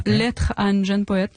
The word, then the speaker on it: Lettre à un jeune poète: How did I do with the pronunciation? – Lettre à un jeune poète. Yeah.